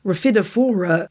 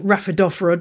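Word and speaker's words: Rhaphidophora: Raphidophora genus, or Raphidophora, depending on which is your preference of pronunciation.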